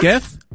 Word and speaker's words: Getth: All right, how do we pronounce G-E-T-T-H as a first name? – Get him. – What? – Geth? What is it? – G-E-T-T-H.